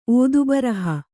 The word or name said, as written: ಓದುಬರಹ